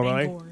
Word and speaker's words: Bangor: We have one of those in Pennsylvania. It's pronounced Banger, not Bangor.